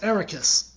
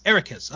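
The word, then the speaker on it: Eraqus: So then, Master Iroquois. Did I say that right? – Almost. Ericus. – Ericus. Okay.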